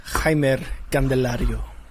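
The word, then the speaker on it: Jeimer Candelario: It is Jaimer Candelario.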